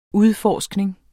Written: udforskning

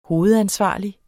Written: hovedansvarlig

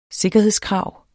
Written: sikkerhedskrav

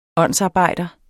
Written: åndsarbejder

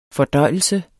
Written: fordøjelse

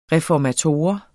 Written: reformator

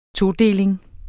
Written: todeling

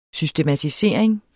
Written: systematisering

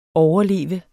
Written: overleve

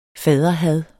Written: faderhad

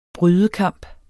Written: brydekamp

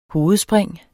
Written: hovedspring

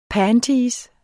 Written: panties

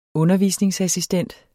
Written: undervisningsassistent

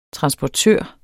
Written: transportør

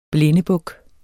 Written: blindebuk

